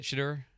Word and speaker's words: Shedeur: Wait, is that how you say it, Shedeur? – Yes.